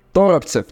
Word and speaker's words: Торопцев: Внимание, Торопцев. Теперь я знаю, как правильно произносить его фамилию.